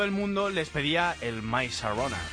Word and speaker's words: My Sharona: ¿Lo he dicho bien? – Mira, ellos te lo dicen. My Sharona. Pues mira, lo habías dicho bien.